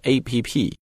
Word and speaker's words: app: 那么拉过来反过来做成它的形容词呢，就是 dog walking app means application、 I think a lot of Chinese people when they want to describe let's say WeChat or 支付宝 Alipay to foreigners sometimes will say this is APP no Yes, it's an app, because app is short for application, and it's spellable, it's pronounceable,、它是可以拼出来的，它可以读出来，所以呢，我们在跟外国人再去介绍这个 APP 的时候，一定直接说 app 就行了，不要说成 APP,、